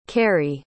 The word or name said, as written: carrie